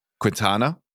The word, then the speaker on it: Quintana: Quintana? No, I said that right.